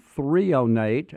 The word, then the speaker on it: threonate: Magnesium threonate, and that's spelled T-H-R-E-O-N-A-T-E, or we call it NeuroMag, and we have it at our office.